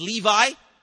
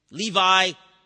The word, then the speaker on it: Levi: Levi or Levi, you can pronounce it different ways. Maybe since he was a tax collector, we should call him Levi.